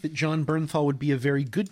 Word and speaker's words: Bernthal: John Barenthal. – Oh, John uh, Bernthal.